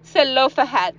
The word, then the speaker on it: Zelofehad: Y dice que muy bien, lo dije bien una vez, tal vez no lo diga bien otra vez. Dilo otra vez, Zelofehad. Muy bien, ese es el nombre número uno en el nombre de bebés.